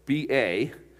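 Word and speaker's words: Ba (八): Well, the word number 8, it's pronounced like B-A. What's B with the A, and then there's a line over the top of it? Ba.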